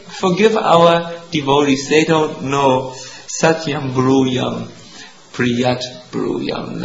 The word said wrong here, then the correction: priyam bruyat: That means to priyam bruyat. Sorry, I said it wrong. I want to say it correctly. Satyam bruyat, priyam bruyat.